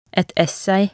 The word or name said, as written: et essay